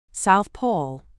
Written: South Pole

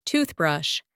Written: toothbrush

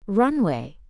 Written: runway

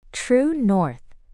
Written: True north

